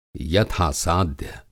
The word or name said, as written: यथा-साध्य